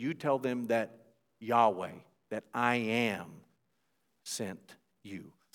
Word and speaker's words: Yahweh: that's the Hebrew word Yahweh, sometimes pronounced Jehovah. I think Yahweh is, most scholars agree, the better pronunciation of that word.